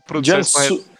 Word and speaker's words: Jiangsu: É, aí você me corrige a pronúncia, o Jiangsu? Jiangsu? Não sei o Jiangsu? Não sei o nome. Qual, Jiangsu, Jiangsu Sunin. – Isso. Gemudo. Jansu. Jansu, ok.